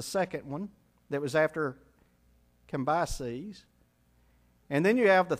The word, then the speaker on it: Cambyses: Cambyses is how you pronounce it. C-A-M-B-Y-S-E-S.